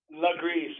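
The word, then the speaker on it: Lagree: The Legree method? – Yep, Legree. So it's L-A-G-R-E-E.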